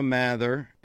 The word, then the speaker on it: Mather: Johnny and I went up on the, how do they pronounce the ship in Cleveland? The Walther? Mather? – Mather.